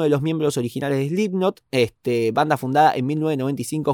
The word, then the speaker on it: Slipknot: El ex baterista de la banda estadounidense de heavy metal Slipknot, Slipknot, ¿cómo se pronuncia? Perdón, no quiero faltar respeto. Slipknot. Perfecto.